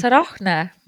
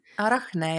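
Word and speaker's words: Arachne: astroloogia sõnastikus vaatame täna asteroidi nimega, nii, kuidas ma teda hääldan? – mina äh, ütleks Arahne, ma ei tea, kuidas. – Arahne, jah.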